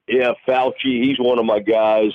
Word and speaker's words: Fauci: Fauci. – Dr. Fauci.